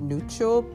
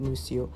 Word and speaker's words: Nuccio: I think the Italians would pronounce it Nuccio, but he's in America, so they pronounce it Nuccio.